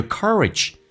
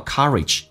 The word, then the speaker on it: courage: We look up to these people because we admire Their spirit, their courage. – 英式发音叫做 courage，美式发音叫 courage，整个舌身往回卷 courage。